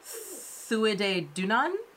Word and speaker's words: Tuatha Dé Danann: so I would normally say this tuatha de Danann, but in learning what the pronunciation is, it looks like it's Thuide Dunan.